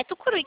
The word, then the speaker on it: Tucuruí: É Tucuruí que fala, né? Ah, Isso, foi. – perfeito. Então, acertei a pronúncia.